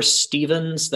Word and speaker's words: Stephans: Uh, so you can find me uh, at coach underscore Stephans.